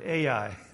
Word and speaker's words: Ai: or did he say Joshua conquered Ai? Either way, there's something there, I think, for us. And I guess it reminds us also that even in a two-syllable word, the accent is important.